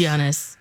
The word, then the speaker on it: Giannis: It's Giannis.